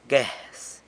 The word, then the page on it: guez: sanlucasquiavini